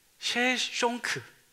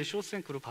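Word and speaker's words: Shoshenq: 학명으로는 셰숑크. 예. 셰숑크거든요. 그게 이제 쇼생크로 발음이 되는 거고, 성경에서는 어떻게 나오냐면 시삭으로 나와요.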